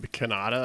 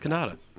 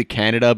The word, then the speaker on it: Kanata: So I know her name's basically Canada, but pronounced with a different emphasis on the different syllable. – It's K-A-N-A-T. – I'm Maz Kanata. – It's K-A-N-A-T-A. – Where are you from? – All right. – It's a T. It's not even a D. – K-A-N-A-T-A.